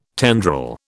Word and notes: tendril: British English

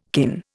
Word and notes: kin: Indian

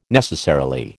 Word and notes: necessarily: American English

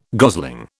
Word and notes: gosling: British English

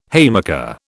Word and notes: haymaker: British English

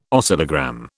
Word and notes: oscillogram: British English

